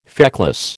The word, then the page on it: feckless: American English